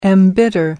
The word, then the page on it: embitter: British English